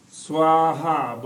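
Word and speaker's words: svāhā: T-U-T, then T, long R-R-E. Om Tare, Tuttare, Ture, T-U-R-E. Swaha, both long A's, Swaha.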